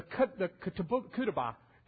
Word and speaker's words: ketubah: And then the ketubah, or the ketubah, is kind of how it's pronounced, kind of how it's said, and this would be the marriage contract, so to speak, or the prenuptial agreement would be a better way to describe it.